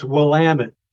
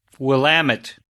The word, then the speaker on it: Willamette: I need to teach you how to pronounce that word. – Okay, I'm ready. – It's Willamette. – Willamette.